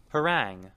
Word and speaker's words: harangue: Harangue. Harangue. Harangue. – Okay, that's how you pronounce it.